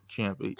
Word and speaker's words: Ciampa: Big T, Tomasa Ciampa, I don't know how to pronounce his name, but I Ciampa. – Like Ciampa. Ciampa.